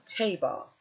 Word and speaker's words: tebah: Now, the word ark in the Hebrew is the word teba. It's spelled T-E-B-A-H. Teba.